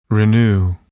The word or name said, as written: renew